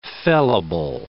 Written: fellable

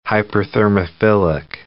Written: hyperthermophilic

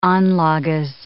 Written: anlages

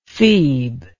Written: feeb

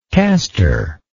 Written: casterless

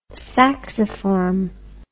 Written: sacciform

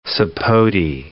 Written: sapote

